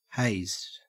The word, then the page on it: hazed: Australian English